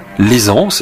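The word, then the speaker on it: Laysan: L'Aisance, ça s'écrit L-A-Y-S-A-N, c'est un petit atoll de l'archipel hawaïen.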